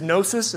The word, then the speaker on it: gnosis: This word knowledge in the Greek is gnosis. It's a silent G, so G-N-O-S-I-S.